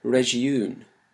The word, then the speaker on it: région: Its medieval French form was région, with stress on the second syllable.